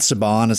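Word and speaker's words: Sabon: And also, I know that people are kind of making jokes about like the name of the font, but like the new font, Saban, is that how you say it? – Yeah, I think that's what it's like. – It's S-A-B-O-N is the name of the font. And I like that as well, like the way it's A-U-B-U-R-N is actually written out.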